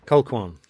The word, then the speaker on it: Colquhoun: Sophie Colquhoun. Is that how you pronounce her surname? – Ribjahak. – Ribjahak. How do you pronounce Sophie's surname? – Colquhoun.